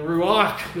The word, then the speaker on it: ruach: And in the Hebrew, it's ruach, and ruach is a very guttural sound when you pronounce it, and it means breath. When you breathe, there's kind of a from the abdomen, from the gut, and so ruach not only means breath, it can mean wind, and it can mean literally spirit.